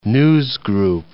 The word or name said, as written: newsgroup